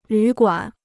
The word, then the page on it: 旅馆: lǚ guǎn